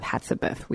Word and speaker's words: Hatshepsut: As I said earlier, the track that we played was from Rhapsody and Queen Latifah, a track called Hatshepsut, we believe. is how you say it. – Yeah, definitely.